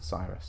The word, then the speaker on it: Cyrus: I'm pretty sure it's Cyrus. – Cyrus. – Cyrus. – Yeah. – Cyrus the Great. That's what it's from.